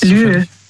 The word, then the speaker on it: Lieu: Lieu. – Lieu? – Also Lieu, das schreibt man also L-I-E und dann U.